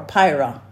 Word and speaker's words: peira: And the Greek word for prove is pira, P-E-I-R-A, pronounced P-I-R-A-H. Pira or pyra is the Greek word for prove.